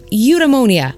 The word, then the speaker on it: eudaimonia: It's got a great name, eudaimonia. Am I pronouncing it right? – Yeah, that's right.